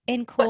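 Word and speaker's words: en caul: Or in, what's the pronunciation? – I'm actually in call. – What does it mean? – It's E-N space C-A-U-L.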